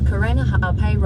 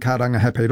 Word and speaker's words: Karangahape: That was TVNZ's Māori Issues reporter Tianiwa Huri Hanganui driving around Auckland and unimpressed with the navigation voice on the Google Maps app mispronouncing Karangahape Road and other places with Māori names.